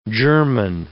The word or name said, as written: German